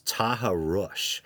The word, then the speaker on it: taharrush: But he discovered this disturbing concept known as Taharush, that's found in certain Islamic cultures, and it's spelled T-A-H-A-R-R-U-S-H.